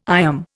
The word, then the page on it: IOM: Indian